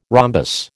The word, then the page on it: rhombus: American English